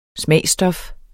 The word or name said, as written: smagsstof